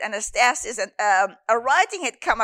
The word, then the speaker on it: anastasis: Anastasis, pronounced anastasis.